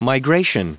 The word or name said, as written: migration